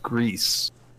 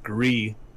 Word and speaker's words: Gris: It's pronounced Grease, means gray in Spanish. – Gotcha. – Okay. – Because it's pronounced gris and means gray in French, I think.